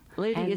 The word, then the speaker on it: Lydie: Liddy is spelled L-Y-D-I-E. – L-Y-D-I-E.